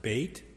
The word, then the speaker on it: Beit: In Hebrew, it's pronounced Beit, and it is also part of this word, B-E-T-H, Beit.